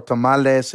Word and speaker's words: tamale: In the United States, it's okay to say tamale. You know, we have black traditions in the Mississippi Delta where they do hot tamales. And the Mexican Americans say tamale, so it's cool.